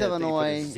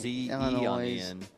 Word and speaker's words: Illinois: Did he pronounce the S? – It's did. Illinois. Illinois.